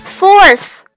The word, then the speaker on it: fourth: Fourth 这个词中的 th 发音时要将舌尖吐出来一点，读作 fourth，fourth，fourth，back and forth，back and forth。